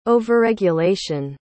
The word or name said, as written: overregulation